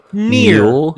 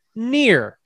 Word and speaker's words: Mjolnir: Mjolnir. – It's so hard to say. I don't know how to do it. – Mjolnir.